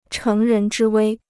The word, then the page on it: 乘人之危: chéng rén zhī wēi